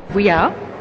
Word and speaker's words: Vuillard: His name is Edward Vuillard, pronounced Vuillard. There's no D at the end.